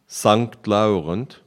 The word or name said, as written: St-Laurent